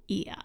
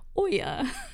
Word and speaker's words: Oia: And I would say in Santorini, you want to go to Ia. – Yes. – And it's spelled O-I-A. Saying that because I probably in the video, if I say it at all in the video, I'm pretty sure I say Oya or something crazy.